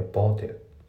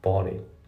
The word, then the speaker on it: bought it: で、発音なんだけど、ボート・イットのところ、そこがまずリンキングで、ボーテル、ね。ボーテル、ボート・イットって言うんですね、ボーテル。あと、 it の t がね、ほぼ発音されてない。抜けてますね。 bought it, bought it, bought it じゃないですね。